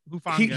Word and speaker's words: Hufanga: And then, I can't pronounce his name. Taufoa. – Hufanga. Hufanga.